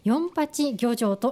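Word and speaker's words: 四十八漁場: 私もね、駅の前でこのお店は見たことあったんですが、漢字で 48 漁場と書いて何と読むのかなとずっと気になっておりました。四八漁場と読むそうですね。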